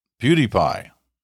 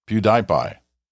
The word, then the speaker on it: PewDiePie: Sorry, I hate to be that guy, but I have to save you from mispronouncing. PewDiePie. That's how it's pronounced, not PewDiePie.